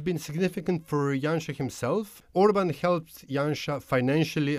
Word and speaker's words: Janša: do we pronounce it Janša or Janša? – Janša.